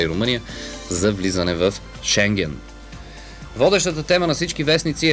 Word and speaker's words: Шенген: Румъния активизира контрола по границите си с тези показни акции, както и България показно се опитва да докаже, че сме готови за Шенген, както е правилното ударение, уважаеми слушатели. Благодарение на министър Младенов знаем точното ударение на Шенген, а не Шенген.